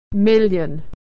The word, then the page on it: million: y